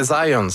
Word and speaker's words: Zajonc: Non, c'est Zajonc. – Ouais? OK. – Ouais, j'ai-tu pas dit Zajonc? – Vous avez dit plus Zajonc, mais. – Ah, parce que j'ai prononcé un petit peu à l'anglais. J'ai prononcé mon polonais à l'anglais.